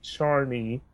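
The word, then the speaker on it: Czarny: Also, I wanted to point out my name is pronounced more like Charny. Char as in Charmander. Yeah, Polish have some weird spelling, I know.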